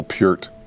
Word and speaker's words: Peart: Reeling from your Neil Peart reference.